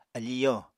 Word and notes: afi yo: Apatani

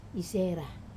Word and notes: sera: Wamesa

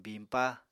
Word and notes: biñpa: Apatani

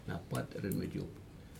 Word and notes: napat irenmejup: Aneityum

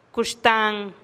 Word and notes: kuxtan: TdVZ

